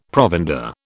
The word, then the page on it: provender: British English